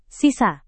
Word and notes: sisa: Indonesian

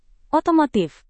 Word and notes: otomotif: Indonesian

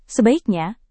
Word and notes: sebaiknya: indonesian

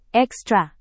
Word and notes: ekstra: indonesian

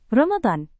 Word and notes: ramadan: Indonesian

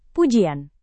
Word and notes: pujian: Indonesian

